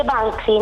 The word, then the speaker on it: Banksy: Si dice Banksy, non si preoccupi.